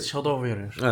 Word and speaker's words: Shadow Warrior: En çok bilindik oyunu e, Shadow War e, Warrior. Warrior. Neydi öyle mi okunuyor? Evet Shadow Warrior.